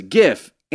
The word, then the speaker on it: GIF: but that says GIF. Animated looping images, hard G.